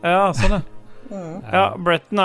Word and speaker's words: Breton: Eh, det er eh, så er det Bretton, eller Breton. Hvordan uttaler man dette her? Bretton, ja.